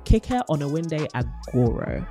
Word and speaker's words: Kike Oniwinde-Agoro: We had the privilege of sitting down with the CEO of the Black Young Professionals Network, BYP, Kike Oniwinde-Agoro.